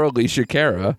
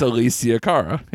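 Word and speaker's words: Alessia Cara: Yeah, because you want to call her Alicia Cara. It's Alicia Cara.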